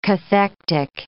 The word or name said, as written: cathectic